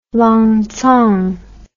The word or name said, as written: Lancang